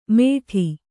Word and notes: ಮೇಢಿ: kannada

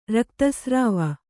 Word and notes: ರಕ್ತಸ್ರಾವ: Kannada

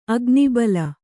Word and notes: ಅಗ್ನಿಬಲ: agnibala

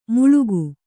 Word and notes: ಮುಳುಗು: Kannada

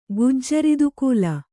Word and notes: ಗುಜ್ಜರಿದುಕೂಲ: Kannada

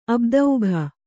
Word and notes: ಆಬ್ದಿಕ: ābdika

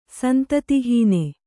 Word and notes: ಸಂತತಿಹೀನ: Kannada

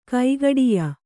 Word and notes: ಕೈಗಡಿಯ: Kannada

